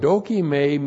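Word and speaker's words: dokime (δοκιμή): D-O-K-I-M, long E, dokime. Point one, dokime means, first of all, testing someone for the purpose of approval.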